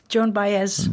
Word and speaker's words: Joan Baez: C'est Joan Baez? – John Baez. – John Baez, vous le prononcez mieux que moi.